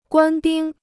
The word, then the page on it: 官兵: guān bīng